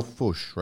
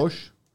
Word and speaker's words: Foch: Nick.Hill at landbankadvisors.ca and Daniel Fush, right? Yep. Fush? I want to make sure I pronounce. – That's better than most people's attempts.